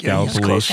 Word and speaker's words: Gallipolis: And Gallipolis. I can pronounce it now, right? – Gallipolis. – Yeah, she's close. She says it with a Youngstown accent, but she'll get there.